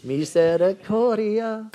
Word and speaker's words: Misericordia: M-E-R-C-Y. – Mercy. – ¿Cómo se dice? Mercy. Misericordia.